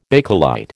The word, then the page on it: Bakelite: American English